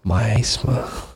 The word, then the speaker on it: miasma: Do you want to say that word in the middle there? – Miasma. – It's miasma. – Miasma? Okay. All right. I've always said that wrong then.